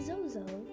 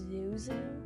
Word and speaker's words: Zozo: and never spell my name Z-O-E-E. Spell my name Z-O-E, or just spell Zozo, Z-O-Z-O. Okay? I also hate when people pronounce my nickname as Zuzu. No, it's not Zuzu. It's not Z-O-O-Z-O-O. It's Z-O-Z-O.